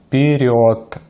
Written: вперёд

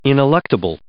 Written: ineluctable

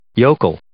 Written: yokel

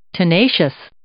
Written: tenacious

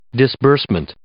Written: disbursement

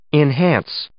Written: enhance

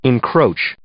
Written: encroach

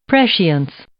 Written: prescience

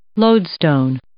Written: lodestone

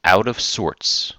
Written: out of sorts

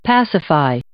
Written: pacify

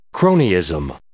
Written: cronyism

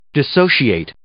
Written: dissociate

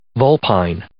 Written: vulpine

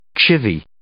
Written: chivy

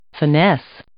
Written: finesse

